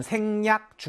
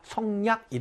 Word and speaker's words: 생략: 반성하다, 자성하다 할 때는 성, 살피다의 뜻으로 쓰였지만, 요렇게 생긴 한자가 덜다의 뜻으로 쓰이면 음이 생이 된다는 것도 기억을 하셔야 됩니다. 중간 생략, 중간 생략 할때 생자를 바로 요렇게 생긴 모양의 한자를 쓴다. 성략, 이라고 읽으면 안 된다라는 얘기입니다.